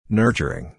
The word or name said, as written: nurturing